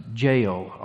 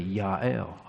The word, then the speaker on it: Yael: And when we get a little further down and we read the name that you probably heard pronounced Jael all the time, it's actually Yael. All the J's usually have the Y sound, which complicates a name like my brother's, which includes both.